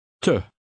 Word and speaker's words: te: The E is unstressed and sounds like the U in put. Read number 10. – Te.